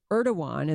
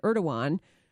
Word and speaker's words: Erdogan: Erdogan, and that is how you pronounce the president of Turkey's name, is spelled E-R-D-O-G-A-N, Erdogan, should do something to free this wonderful Christian husband and father.